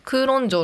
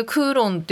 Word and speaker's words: 九龍: っていう漫画だなんかこれねこの漫画を読んだ時に改めてクーロン城のことを調べたんですよでそしたら日本での名称って九龍城なんだってえじゃあなんで私ってこれクーロン」って読